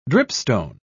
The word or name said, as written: dripstone